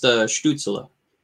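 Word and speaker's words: Stützle: It's actually pronounced Stutzla.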